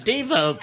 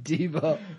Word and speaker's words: Debo: Debo. – Oh, that's Debo. – What? Pretty sure his name is Devo. – Devo. – But they were pronouncing it Debo like the Latins do.